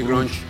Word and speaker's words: grunge: También está en los géneros de grunge o grunge, ¿cómo se dice eso? – Sí, grunge.